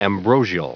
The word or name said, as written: ambrosial